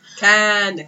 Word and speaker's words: Caan: Caan. – C-A-A-N. – Can.